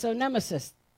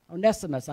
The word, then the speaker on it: Onesimus: So what's this all about, this Onesimus guy? Onesimus, I should say. I said it wrong there.